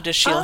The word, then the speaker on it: Dashiell: So think of it in the French way, and the C-H is pronounced like Chicago, or the S-H is pronounced like Chicago. But it's Dashiell.